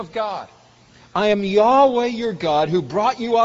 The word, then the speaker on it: Yahweh: We're not precisely sure, but almost certainly it's pronounced Yahweh. There's a word for God that describes him as God in kind of generic terms, Elohim, God, the Mighty One. But Yahweh is his personal name. The older way of pronouncing it, and I won't explain why it's a mistranslation, is Jehovah. And that's based on a misunderstanding of the Hebrew passage.